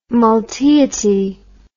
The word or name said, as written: multeity